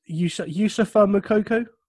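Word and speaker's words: Youssoufa Moukoko: Yusufa Moukoko? – Oh, very good.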